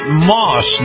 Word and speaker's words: Maus: This portion of the Captain's America Third Watch is brought to you by our friends at Moss Nissan. That's spelled M-A-U-S, Nissan.